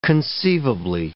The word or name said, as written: conceivably